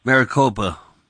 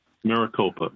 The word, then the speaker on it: Maricopa: Maricopa. – Maricopa, right.